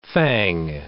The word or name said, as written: fang